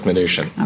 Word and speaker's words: Mnuchin: Stephen, not Steve, Mnuchin, not Mnuchin or Mnuchin or whatever people said.